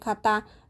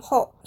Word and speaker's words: 方: 虽然都是方正的方，但是发音不一样。使用在人上面，发音会念咔哒；使用在物品上面，发音会念后，所以非常容易被混淆。